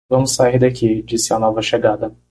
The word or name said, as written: chegada